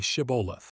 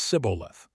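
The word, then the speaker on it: Shibboleth: If he said, "No," then they said to him, "Now say Shiboleth." And he said Siboleth, for he did not accomplish correct pronunciation.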